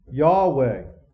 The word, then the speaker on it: Yahweh: In the Old Testament, the personal name of God, does anybody know what the Hebrew pronunciation is? Yahweh. The German pronunciation is what? Jehovah. And the reason why that's the Germans pronounced it, because it in the Hebrew, it's actually Yah, like a hard breathing sound. And the Germans couldn't pronunciate that, so it was a Jah sound.